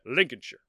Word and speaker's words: Lincolnshire: So anytime you just see the shire at the end of a word, it's pronounced sure. You just think of New Hampshire. So it's yeah. Lincolnshire.